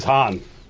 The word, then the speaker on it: Han: How do you pronounce it? – It's Han.